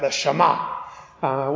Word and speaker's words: Shema: So you've got to say it like that, the Shema. When you say Hebrew things, there's a sort of guttural kind of sound to it.